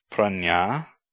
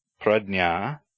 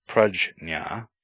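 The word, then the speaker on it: prajñā: The consonant cluster J and tilde, found in many words derived from the root nya, no, can be pronounced as N-N-Y or D-N-Y or G-Y, but not J-N. Thus, the word for wisdom is pronounced pranya, pradnya, or pragya, but not prajna or prajna.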